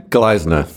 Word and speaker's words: Gleisner: Gleisner.